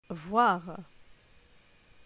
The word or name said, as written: Voir